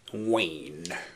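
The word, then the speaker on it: Wain: Es geht natürlich um Louis Wayne. Ich hoffe, ich habe ihn richtig ausgesprochen. – Wayne.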